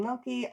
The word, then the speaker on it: Melky: If I were to summon a swarm of Katrine Melky, I would say... it rhymes with Selkie.